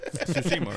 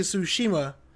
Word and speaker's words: Tsushima: I'm down to play Ghost of Tsushima, man. – You can only say that a few more times before it becomes radically offensive. – Yeah, I know. Tsushima, right? – They pronounced it differently in the thing.